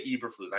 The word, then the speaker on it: Eberflus: I, everyone says like Eberflus. I say Eberflus. I know the E is silent, it's pronounced with an I.